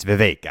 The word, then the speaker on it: Vivek: I mean, I even now know how to say his name. It's Vivek, actually.